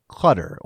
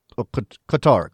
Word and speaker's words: Qatar: So I've been told, you've been told, right, the proper way to say it, the closer to, we're not going to get the accent right, but the closest is like Qatar or Qatar, Qatar.